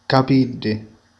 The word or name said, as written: capelli